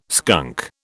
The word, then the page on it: skunk: British English